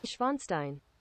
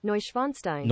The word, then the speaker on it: Neuschwanstein: Neuschwanstein. – Neuschwanstein? – Neuschwanstein. – Neusch. – Neusch. – Neuschwanstein. – Neuschwanstein. Turn up that Bluetooth. – There you go. – Let's do it again. – Neuschwanstein.